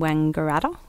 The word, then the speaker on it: Wangaratta: Mark and Kobe, they were both seen in Wangaratta.